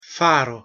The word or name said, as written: faro